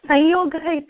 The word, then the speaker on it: संयोगः: संयोगः इति वक्तव्यम्, उच्चारणं भवेत् इति महोदयेन पूर्वम् अस्माकं वर्गः आसीत्, ध्वनिमुद्रणं महोदयस्य एव जलस्थाने अस्ति. अनुनासिकयोः भेदः इति कृत्वा महोदय पाठितवान्.